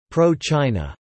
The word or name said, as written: pro-China